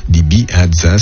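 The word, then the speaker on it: Ibihaza: Libi Hadza, ça s'écrit si vous voulez l'écrire avec un I, un B, un I, un H, un A, un Z et encore un A.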